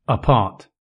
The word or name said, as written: apart